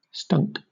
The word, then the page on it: stunk: British English